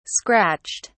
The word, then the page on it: scratched: British English